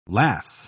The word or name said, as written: lass